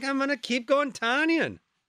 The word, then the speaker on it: Tonyan: The big news out of Packers camp yesterday is that Robert Tonyan's name is actually pronounced Robert Tonyan. Tonyan.